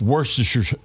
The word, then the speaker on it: Worcestershire: Worcestershire. – Yeah. See, I think you got too many syllables in there. It's just Worcestershire.